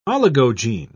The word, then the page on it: oligogene: British English